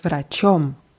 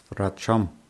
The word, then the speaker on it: врачом: С врачом. С врачом. – Ja, die Betonung ist auf O.